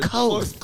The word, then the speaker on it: Koth: It's Koth, but Koth. Close enough. – Koth. How can I spell that? – It's K-O-T-H. – That's how you spelled it, but how can I spell it where I'll pronounce it right? How's that? K-O-Koth. – Yeah. – Koth. What does that rhyme with? – It's sort of Kuth, but not.